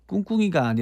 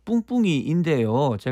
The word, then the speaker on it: 뿡뿡이: 아하, 2919님 포함해서 여러분들이 1번으로 보내주셨고 또 봄꽃 향기 나네님도 정답 4번 꿍꿍이, 꿍꿍이가 아니라 뿡뿡이인데요. 제가 발음이 좀 부족했나 봅니다.